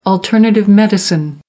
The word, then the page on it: alternative medicine: American English